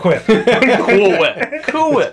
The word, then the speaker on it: Cool Whip: I want some Cool Whip. – Oh, yeah, we did that too. – Cool Whip. Cool Whip. Why are you putting so much emphasis on the H?